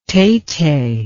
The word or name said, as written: tete